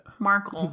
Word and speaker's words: Markle: Wait, how did you say her last name? – Meghan Merkel. How do you say it? – Markle. – Did I do it? – Markle. – How do you say it? Is it Meghan Markle? – It's Markle. Markle. – Oh. – Not Urkel with an M.